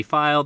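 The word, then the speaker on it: Feil: R-E-I-D is how you spell that. – I am also on Twitter at Chris V. File. That's F-E-I-L.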